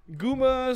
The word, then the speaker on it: Goomba: Goomba or Gumba? Let's say I'm Googling it. Gumba. How do you spell that? G-O-O-M-B-A? – Yes. – Ah, okay, that's called Goomba then.